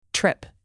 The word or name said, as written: trip